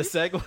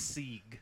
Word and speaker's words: segue: It's Sieg. – It's pronounced segue. – It's Sieg. Trust me.